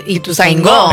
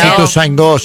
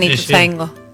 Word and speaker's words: Ituzaingó: No sé, para mí es Ituzaingó. Tiene acento en la O. – Es Ituzaingó, sí. – Pero le dicen Ituzaingó.